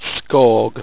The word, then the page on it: sqawg: owstwecian